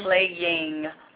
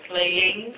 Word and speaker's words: plaguing: Plaguing, you know, there's more than one syllable in that word. Mm. Plaguing. Plaguing. – Yeah, whatever, they're playing.